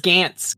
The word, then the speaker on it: Gaetz: I thought it was Gaetz. Like G, like Ant with a G. – It's G-A-E-T-Z. – There's no N. I think Gaetz. I think it's like Bill Gaetz. It's the same pronunciation.